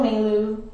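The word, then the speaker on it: Mailoux: Yeah, it's Michelle Melu 95. – Really? – Yeah, because the X is silent. It's in my last name.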